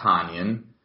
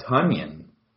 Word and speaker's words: Tonyan: Robert is actually not a Tonyan, but he's a Tunyon, as in Funyon, or Onion, as in rhymes with John Runyon.